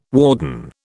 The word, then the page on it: warden: British English